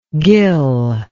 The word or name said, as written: gill-like